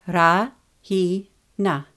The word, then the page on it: Rahina: Ra–hi–na